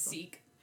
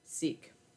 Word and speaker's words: Sikh: It's Sikh. – Sikh.